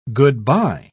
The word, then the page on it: good-by: British English